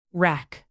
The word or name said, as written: rack